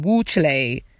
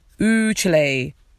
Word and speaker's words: Ooh, Chile: and pronounced it wrong, used it incorrectly, saying Wu Chile. Ooh, Chile.